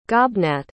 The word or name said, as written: gobnat